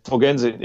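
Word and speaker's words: på gensyn: In Dänisch würde man sagen, Pogensyn, auf Wiedersehen und. – Genau, dann sage ich jetzt Pogensyn, wenn ich das richtig ausspreche. – Pogensyn, ja. Oder alles Favell.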